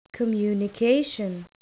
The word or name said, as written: communication